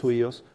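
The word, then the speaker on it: huios (υἱός): La otra palabra que encuentras en el Nuevo Testamento, más comúnmente, que se traduce como hijo, se escribe H-U-I-O-S, huios. Esto se traduce en sentido metafórico. Cuando ves hijo de en la Biblia, casi siempre es huíos, pero casi siempre se traduce metafóricamente o el significado es metafórico.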